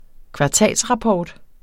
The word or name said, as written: kvartalsrapport